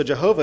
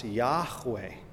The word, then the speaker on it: Yahweh: So Jehovah is actually not a name of God. It's wrong. It's an English pronunciation of a German attempt to put into speech God's divine name. We know that the YHVH in Hebrew is probably pronounced Yahweh.